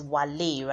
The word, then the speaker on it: Wale: So we in Nigeria will probably call him Wale, but you know, he is Wale right now. So we respect how he pronounces his name.